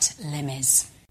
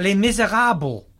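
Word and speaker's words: Les Misérables: She doesn't say it right, does and she? Can't, Les Miserables. – Yeah. – Les Miserables. – Les Miserables. – Les Miserables. And, yeah, and all the way through, but her and Matt sort of struggled with the pronunciation of... Matt Bacon called it Le Miserable.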